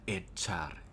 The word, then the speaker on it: echar: echar e c h a r echar el verbo คำนี้นี่สามารถใช้ได้หลายความหมายนะครับแปลว่าใส่ก็ได้หรือว่าเคยได้ยินเขาว่า echar